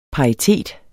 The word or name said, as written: paritet